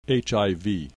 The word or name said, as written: HIV